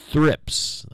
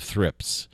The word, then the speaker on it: thrips: Oh, well, anyway, which unusual flower is pollinated by gnats and the larva of thrips? I think that's the way you pronounce it. T-H-R-I-P-S.